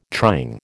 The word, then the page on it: trying: American English